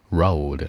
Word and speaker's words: road: 那注意这个发音啊，之前就说过，在路上 on the road，不是肉的啊，on the road 还 on the 菜的呢。注意发音，前面加个小窝，road road on the road。